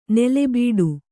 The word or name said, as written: ನೆಲೆಬೀಡು